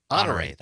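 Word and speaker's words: Honoré: Also, General Russell Honore, is that how you pronounce it, I believe, discussing military. – Honore.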